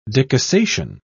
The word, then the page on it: decussation: British English